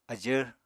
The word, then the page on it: ajvr: apatani